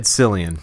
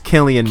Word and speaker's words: Cillian: 10 years ago, so, I found out that it wasn't Cillian, it's Killian Murphy.